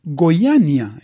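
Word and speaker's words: Goiânia: A ver si lo dije bien. Goiania. Goiania es como se debe decir,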